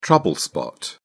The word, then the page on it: trouble spot: British English